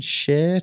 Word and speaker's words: Cher: What did Cher tweet? I always find it hard to say her name. C-H-E-R. Is that Cher? – Yeah, people say Cher. People say it like Cher.